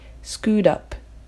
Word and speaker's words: scoot up: 発音の注意点としては scoot up がつながって t の音が柔らかい d のような音になり scoot up, scoot up となる